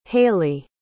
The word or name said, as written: halie